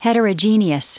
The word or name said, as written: heterogeneous